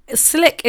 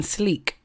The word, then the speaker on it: Slique: So Slick is spelled S-L-I-Q-U-E, and I kept saying sleek, right?